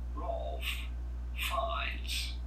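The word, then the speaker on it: Ralph Fiennes: We are looking at how to pronounce Rolf Fiennes.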